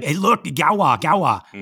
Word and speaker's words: Gauloises: But then I started looking at the I forget how you spell these. The Galwa. He says it in the audiobook. He, he pronounces it Galwa. It's G A U L O I S E S. Pronounced Galwa or Gauloises.